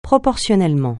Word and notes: proportionnellement: francais

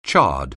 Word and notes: char: anglais

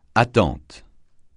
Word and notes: attente: francais

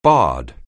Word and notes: barred: anglais

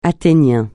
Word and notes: athénien: francais

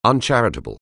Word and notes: uncharitable: anglais

